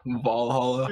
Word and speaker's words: Valhalla: Valhalla. – I'm going to write that down. – It's like a long A. Valhalla. – Valhalla.